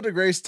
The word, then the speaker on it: deGrasse: So, Neil deGrasse Tyson. – You don't say his name right. – It's DeGrasse, nigga.